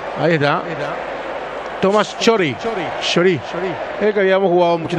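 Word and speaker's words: Chorý: Chori. Chori. Acento en ahí entonces. – Con Y al final.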